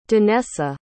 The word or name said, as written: danessa